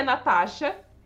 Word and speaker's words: Nastasia: Então- meu nome é Natasha. É, se escreve Nastasia, que é em russo.